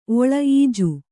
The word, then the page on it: ಓಳಿ: Kannada